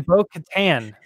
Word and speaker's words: Bo-Katan: She pronounces her name Bo-Katan. So I was like, oh, Bo Settlers of Catan.